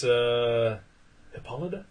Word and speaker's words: Hippolyta: Faust takes Hippolyta? Is that how yes. – you pronounce it? – Yes. – Hippolyta.